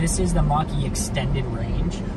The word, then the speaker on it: Mach-E: The Mach-E. – Yes. – Okay. – The Mach-E.